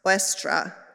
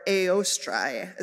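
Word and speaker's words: Eostre: Oestra, or Eostrae is actually how it's pronounced in Old Germanic.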